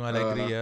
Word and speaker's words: Allegri: Yeah, Massimo Allegri.